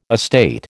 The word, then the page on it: estate: American English